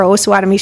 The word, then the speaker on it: Osawatomie: It's going to be Always and Forever Osawatomie Shelter. And it only took me 4.5 years to learn how to say Osawatomie properly. It is not Osawatomie.